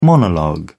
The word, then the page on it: monologue: British English